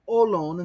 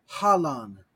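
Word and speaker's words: olon (ὅλον): Remember that in the Koine Air pronunciation, when we have rough breathing marks, we don't do the sound, the aspiration, as in Erasmian. That's just smooth, right? Psilon pneuma, really, the dasu pneuma and psilon pneuma work the same way. They're both smooth in terms of prophora, pronunciation. So olon instead of halon. You can hear there, that's a really good example, Fred, of where Koine Air pronunciation, Kep and Erasmian, sound very different. Halon versus olon.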